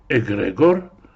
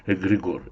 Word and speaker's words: эгрегор: А в еврейском контексте есть Ирим, которые названы стражами, или в греческих текстах часто называется словом эгрегор, слово, которое очень любят эзотерики, часто меняя ударение на эгрегор.